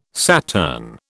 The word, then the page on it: Saturn: British English